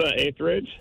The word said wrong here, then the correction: Etheridge: Melissa Etheridge.